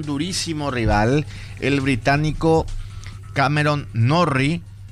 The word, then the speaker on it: Norrie: Cameron Norrie, no es Norris, porque mucha gente se confunde con Norris Landon, el corredor de Fórmula 1, los dos son británicos, pero este es Norrie. – Este Norrie.